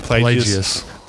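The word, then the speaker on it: Plagueis: Plagius.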